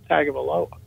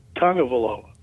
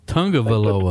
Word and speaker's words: Tagovailoa: And if I'm him, and if they're at number seven, and they believe Tua, by the way, I just found out it's not Tagovailoa. It's Tagovailoa. – Tagovailoa.